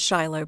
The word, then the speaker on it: Siloam: So the spelling is S-I-L-O-A-M. – Oh. – But pronounced Shiloh Presbyterian.